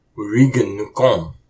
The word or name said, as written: Wurikun nukô!